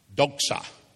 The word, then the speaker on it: doxa: Well, if you read the context of it, to be sent means to be invested with a Greek word that in English we would pronounce doxa, D O X A.